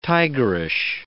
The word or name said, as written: tigerish